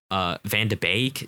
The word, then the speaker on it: Van de Beek: You have De Ligt, De Jong, Van de Beek. Is that how you pronounce it? – Yep.